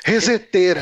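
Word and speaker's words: ResetEra: Que é o Reset Era. É, reset, e... é, reset era, não sei como se pronuncia. Reseteira. – Reseteira, claro que é reseteira.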